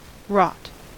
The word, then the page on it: wrought: American English